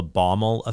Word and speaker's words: Baumol: It's called the Baumol effect, B-A-U-M-O-L, Baumol effect, or what's also called Baumol's, I hope I'm pronouncing this right, Baumol's cost disease.